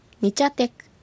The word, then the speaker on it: ニチャテク: はい、ニチャテク、これは木の枝を表すアイヌ語です。 – はい、最後のクは小さいクの発音ですので注意して発音してください。